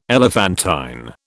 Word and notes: elephantine: British English

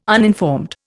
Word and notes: uninformed: Indian

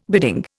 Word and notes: bidding: Indian

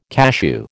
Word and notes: cashew: American English

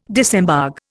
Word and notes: disembogue: Indian

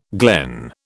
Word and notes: glen: British English

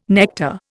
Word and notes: nectar: Indian

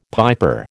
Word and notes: piper: American English

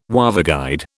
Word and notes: waveguide: British English

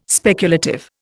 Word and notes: speculative: Indian

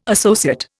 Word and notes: associate: Indian

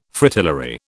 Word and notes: Fritillary: British English

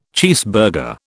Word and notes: cheeseburger: British English

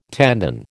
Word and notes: tendon: American English

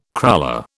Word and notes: cruller: British English

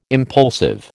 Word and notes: impulsive: American English

